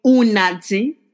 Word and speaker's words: Unati: But you can call me Unati. It is spelled U-N-A-T-I, but it is very much Unati.